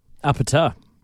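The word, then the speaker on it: APT.: How do you say that song? – Apata. You just absolutely nailed it.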